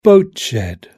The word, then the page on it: boat shed: British English